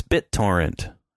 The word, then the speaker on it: BitTorrent: You were mad about it, but it's BitTorrent. That's how you pronounce that.